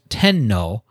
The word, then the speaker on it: Tenno: Put them together and it is pronounced Tenno, and the meaning is heavenly sovereign.